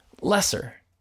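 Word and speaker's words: Loesser: First, when we were talking about Baby It's Cold Outside, you and I didn't know how to pronounce the name of the writer. Well, his name is actually Frank... – Lesser? – Lesser.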